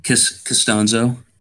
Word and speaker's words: Castonzo: Castonzo.